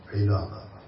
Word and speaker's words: العلاقة: طبعاً بالنسبة للعلاقة الأحسن أن تنطق بكسر العلاقة، والعلاقة هي المشابهة.